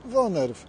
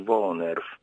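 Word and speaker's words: woonerf: Mówimy wonerw, wunerw, z urzędników różnie przypadają określenia. – Było sprawdzane. Słowo jest konkretne, pochodzi z języka niderlandzkiego i prawidłowo je się wymawia jako wonerw.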